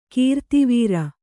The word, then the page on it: ಗುರುದ್ವಾರ: guru dvāra